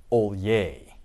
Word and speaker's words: Hollier: But my favorite are the two that I underlined there LSU Tiger and LSU, it's pronounced Olye, but it's spelled Hollier.